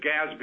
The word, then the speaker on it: GASB: It's GASB.